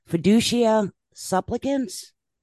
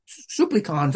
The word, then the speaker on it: Fiducia supplicans: right, now we're going to talk about I'm going to murder the pronunciation. Fiducia supplicans? – Supplicons, that's pretty good. – Supplicans. Okay. – Supplicons, yeah.